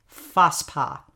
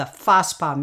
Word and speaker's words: FASPA: Then at maybe 3 or 4 o'clock in the afternoon, there would be something called FASPA, F-A-S-P-A. And I realized when I was looking that word up and researching this story that FASPA is an idiosyncratic pronunciation of the word Vesper.